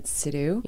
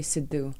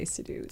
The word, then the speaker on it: Sidhu: You know, it's funny, growing up in Canada, I've always just said Sidhu. – Yeah. – But in India, say it right. I think it's actually Sidhu. – Sidhu. – But I actually only ever say Sidhu.